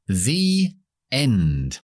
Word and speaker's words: the end: O cuando terminan las películas dice the end el final, pero the, esa palabra, se pronuncia con la i larga porque tenemos una vocal después. Pero para enlazarlas, esas dos palabras, metemos la y.